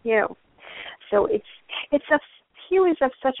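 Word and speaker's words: HU: And when we put our attention here with our eyes closed, we're going to chant the word Hue, which is spelled H-U, and it simply sounds like I just said, except it's drawn out.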